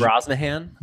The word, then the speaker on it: Brosnahan: The main girl, Rachel Brosnahan, I don't really know how to say her last name, and I've never saw anything that she's been in, but she was amazing. – She's Brosnahan. – Brosnahan, sure.